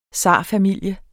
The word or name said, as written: zarfamilie